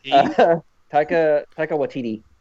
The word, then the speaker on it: Taika Waititi: No, it's not how you say his name. Can you pronounce his name? – Probably not. Taika Waititi.